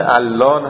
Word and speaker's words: علان: عرض کنم خدمت شما، این کلمه علان هم ظاهرا تلفظ محلی خود علیه، خود علی رو به لهجه علان تلفظ میکنه.